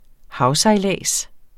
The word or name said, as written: havsejlads